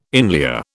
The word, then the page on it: inlier: British English